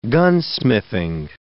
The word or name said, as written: gunsmithing